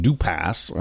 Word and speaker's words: Dupas: It's pronounced D-U-P-A-S, and I pronounced it Dupas or something like that last week. But he's got the fancy silent S at the end of his name, so it's Dupas.